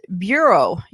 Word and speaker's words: Burou: His name was Georges, G-E-O-R-G-E-S, and it's spelled B-U-R-O-U, and Andy looked it up, and it pronounces bureau, you know, like Bureau of Land, Bureau of, you know, Government Bureau.